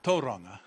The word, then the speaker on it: Tauranga: Now pronounced Tauranga. – Teraz to się troszeczkę inaczej wymawia. – The native people want the native word spoken the right way.